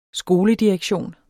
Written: skoledirektion

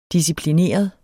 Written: disciplineret

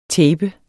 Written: tapet

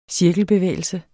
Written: CIRKLEN

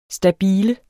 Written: stabile

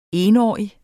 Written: etårig

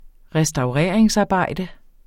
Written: restaureringsarbejde

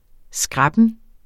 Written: skræppen

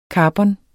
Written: karbon

